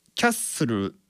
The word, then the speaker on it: Castle: そして、まあ、あとはですね、サビとかですね、 Building Castles in the Sky、これも Castles in のこの図っていうのと in っていうのがつながって Castles in ってなってるんですよね。さらにその L の発音、 Castle の L の発音っていうのはこれ日本語のルのような発音ではないんですよ。ウとかオのような発音に近い、まあ、あるいは全く聞こえてないかなっていうふうな発音になっているんですよね。